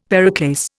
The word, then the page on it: periclase: Indian